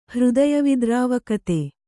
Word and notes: ಹೃದಯವಿದ್ರಾವಕತೆ: kannada

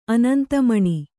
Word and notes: ಅನಂತಮಣಿ: anantamaṇi

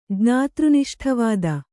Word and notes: ಜ್ಞಾತೃನಿಷ್ಠವಾದ: jñātř niṣṭha vāda

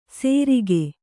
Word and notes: ಸೇರಿಗೆ: Kannada

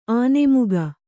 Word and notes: ಆನೆಮುಗ: kannada

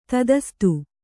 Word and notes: ತಥಾಸ್ತು: tathāstu

